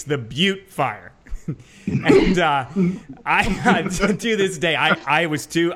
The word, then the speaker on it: Butte: But back in 2008, there was a fire called the Butte Fire, and it's spelled B-U-T-T-E. And, you know, we've all hit the air and not read scripts before, right? I'm not alone in that. So I called it the Butt Fire.